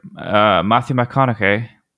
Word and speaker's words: Matthew McConaughey: but Matthew McConaughey. That's how you say his name? – You got it. You nailed it.